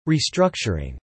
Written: restructuring

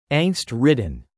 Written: angst-ridden

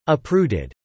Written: uprooted

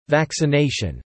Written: vaccination

